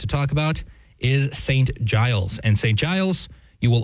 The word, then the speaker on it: Giles: And it's Giles with a G for those of us who pronounce, give correctly. It might confuse a little.